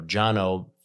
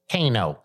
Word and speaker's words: Cano: So, you know, Jano, first name is Armenian. It's spelled C-A-N-O, but it's pronounced Jano, which means here in Nashville, everybody pronounces it as a Kano.